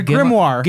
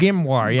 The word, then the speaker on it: Grimoire: The Grimoire. – Grimoire, yeah. – Grimoire cards, yeah. – Just have a tab in the main menu. Like, that's all. – I was fascinated by reading those Grimoire cards.